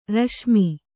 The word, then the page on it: ರೇಶಿಮೆ: rēśime